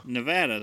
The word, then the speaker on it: Nevada: Nevada, that is how you say it.